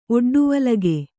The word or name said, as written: ಒಡ್ಡುವಲಗೆ